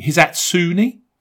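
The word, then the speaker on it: Hisatsune: Ryo Hizasune. Hizatsune? – Yeah.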